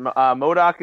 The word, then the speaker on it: MODOK: MODOK is one of those, one of the more obscure characters, if you will, from Marvel Comics.